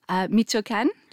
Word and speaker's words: Michoacán: Michoacán department? – Yes.